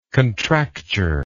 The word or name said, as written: contractured